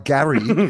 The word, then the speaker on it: Gary: You can't do that with a name like Gary. And I'm like, Gary rhymes with Carrie.